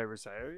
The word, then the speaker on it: Rousseau: if that is the correct pronunciation of that name. – I would say Rousseau, yeah.